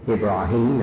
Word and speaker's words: Ibrahim: Ibrahim, as it's called, as his name is pronounced in Islam, Ibrahim.